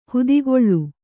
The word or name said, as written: ಹಿತಗೊಳ್